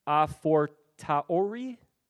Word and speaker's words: a fortiori: And this is known as, and I'm probably going to not say this correct, so I'll just say that right off the bat, But I believe it said a fortiori. Yes, there we go. I've heard it a little smoother out there.